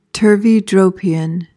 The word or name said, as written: turveydropian